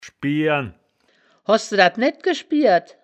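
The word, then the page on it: schbieren: Wittlicher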